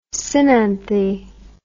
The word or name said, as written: synanthy